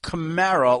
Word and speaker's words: Kamara: He was in Bristol, and we went over this, and he said it's Camara, like the car Camaro, right. – But Camara. But sometimes I slip back and go Camara.